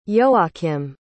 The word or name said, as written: ioakim